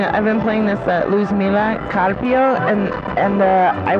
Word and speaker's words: Luzmila: Luzmila. Say that again. Luzmila. Like loose, like light, L-U-Z.